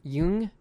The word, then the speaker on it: Jung: It's spelled that way, but it's... – J-U-N-G. – Why is it pronounced that way? Is it? – I think it's because he's German.